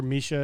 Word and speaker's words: Misha: I prefer Misha. It's the Russian pronunciation of my name.